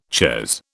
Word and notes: chez: British English

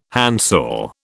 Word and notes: handsaw: British English

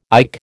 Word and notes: IC: American English

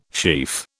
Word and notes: sheaf: British English

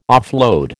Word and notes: offload: American English

